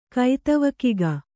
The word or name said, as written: ಕೈತವಕಿಗ